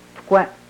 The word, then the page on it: pkwem: Yurok